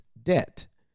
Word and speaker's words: debt: debt I hate the word debt because that B is silent and it's just stupid.